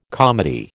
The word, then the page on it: comity: American English